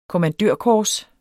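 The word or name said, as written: kommandørkors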